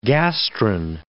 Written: gastrin

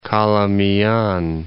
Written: Calamian

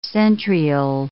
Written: centriole

